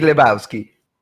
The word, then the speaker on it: Lebowski: Big Lebowski.